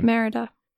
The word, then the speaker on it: Merida: Merida, or however you say her name. – Yeah, Merida.